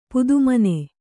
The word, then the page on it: ಪದುಮಿನಿ: padumini